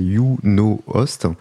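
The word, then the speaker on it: YunoHost: Alors YouNost, c'est le Y qui se prononce Y en anglais. Donc c'est YouNoHost.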